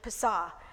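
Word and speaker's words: Pesach: It looks very different, but it's actually Pesah.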